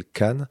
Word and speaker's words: Kaan: Mais juste avant, on va s'écouter un artiste trop méconnu à mon avis, qui s'appelle Khan. Ça s'écrit K-A-A-N avec des points à chaque fin de lettre.